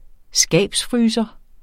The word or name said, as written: skabsfryser